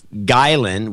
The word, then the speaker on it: Gielen: It's Chris Gielen with an I before E, pronounced in Flemish.